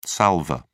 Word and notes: salvor: British English

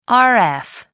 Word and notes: rf: American English

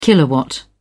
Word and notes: kilowatt: British English

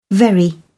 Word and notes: very: British English

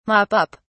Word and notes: mop up: British English

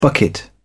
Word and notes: bucket: British English

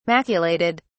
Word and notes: maculated: British English

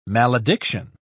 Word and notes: malediction: British English

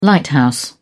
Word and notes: lighthouse: British English

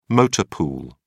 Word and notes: motor pool: British English

